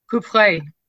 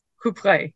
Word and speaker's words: Coupvray: Let me spell that for you. C-O-U-P-V-R-A-Y. Coupe-Vraille. Coupe-Vraille.